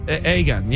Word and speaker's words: Aegon: Aegon, yeah.